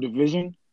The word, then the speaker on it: DVSN: They're called Division, and it's spelled DVSN.